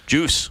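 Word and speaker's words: Djoos: And how do you pronounce his last name? Jews. Juice. – Juice. Yeah. Juice.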